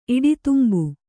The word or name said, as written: ಇಡಿತುಂಬು